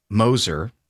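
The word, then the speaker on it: Gmoser: Then the county prosecutor, Mike Moser, silent G, G-M-O-S-E-R, appealed the county's own board to the Common Pleas Court.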